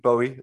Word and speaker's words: Bowie: Well, you know, David always said rhymes with slowy. – Okay, well, slowy Bowie.